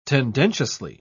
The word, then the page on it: tendentiously: British English